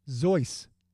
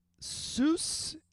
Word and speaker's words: Seuss: How did he and his family pronounce their last name? – Suez? – His last name. – Suez? – Nope. Zeus. – Were they German? – Seuss is how people pronounce it. Zeus is the pronunciation used by his family.